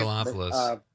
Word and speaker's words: Megalopolis: But like, I don't know, there's been a couple lately, like the new Joker movie and the Francis Ford Coppola movie, Megalopolis. Am I saying that right? – Megalopolis.